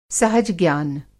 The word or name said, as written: सहज-ज्ञान